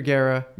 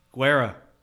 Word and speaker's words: Guerra: Bullpen is Junior Guerra, Matt Albers, Taylor Williams. What? – The way you pronounce Junior's last name. – What is it? – Guerra. – Is it? You sure? – Yes.